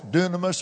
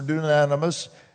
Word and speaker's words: dunamis: The meaning of that word power, it's dunamis or dunamis, pronounced both ways.